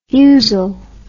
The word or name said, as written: fusel